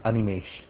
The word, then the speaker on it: Animesh: My name is Animesh.